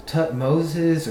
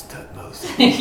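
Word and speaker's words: Thutmose: And then, like, somebody's like, yeah, you call him Tutmosey, but I heard on a documentary they call him Tutmoses or Tutmos. And I was like, it's Tutmosey.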